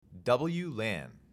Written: WLAN

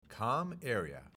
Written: COMMAREA

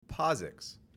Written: POSIX

